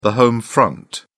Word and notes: home front: British English

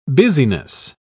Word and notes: busyness: British English

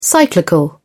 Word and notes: cyclical: British English

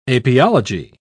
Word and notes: apiology: British English

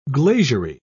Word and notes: glaziery: British English

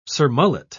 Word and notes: surmullet: British English

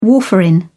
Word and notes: warfarin: British English

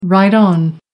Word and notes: right on: American English

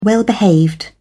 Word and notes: well-behaved: British English

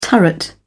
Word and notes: turret: British English

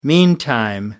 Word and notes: meantime: American English

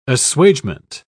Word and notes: assuagement: British English

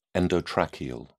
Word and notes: endotracheal: British English

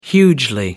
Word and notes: hugely: American English

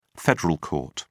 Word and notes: federal court: British English

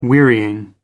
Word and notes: wearying: American English

